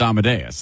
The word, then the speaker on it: Amadeus: Because if you've ever heard it, you know it's Amadeus.